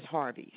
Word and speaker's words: Harvey: My last name is Harvey, spelled like Steve, like HARVEY.